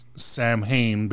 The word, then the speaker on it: Samhain: Because the one thing I did see is that the Sam, because it's spelled Samhain, basically. – Yes. – But it's Samhain.